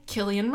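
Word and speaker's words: Cillian: But there's an example of a C being pronounced like a K. Killian Murphy.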